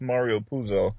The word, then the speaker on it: Mario Puzo: Great, yeah, shout out to Mario Puzo.